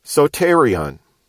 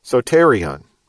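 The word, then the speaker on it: soterion (σωτήριον): The word salvation in English is, in Greek, the word soterion, soterion, and we could spell that S-O-T-E-R-I-O-N. And if you spelled it out, you could put a mark over the O and over the E, and that would show you that those are long letters.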